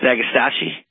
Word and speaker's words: Agastache: Next up, we've got Alistair Maine with a question called Agastache. I believe I'm saying it right. – You're close. It's Agastache.